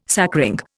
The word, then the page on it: sacring: Indian